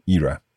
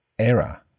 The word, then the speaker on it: era: So we could talk about the Elizabethan era or the Victorian era. Can this word also be pronounced as era? – It absolutely can. Some people pronounce it era and some era. There's no difference.